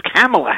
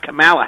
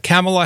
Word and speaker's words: Kamala: But apparently it's Kamala Harris, not Kamala Harris. – Yeah, it's interesting because K-A-M-A-L is it does have different pronunciations.